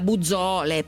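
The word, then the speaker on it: Buzzoole: È il capo di marketing da Bazool, anche se a me piace anche chiamarla Buzzole, perché così capite come si scrive.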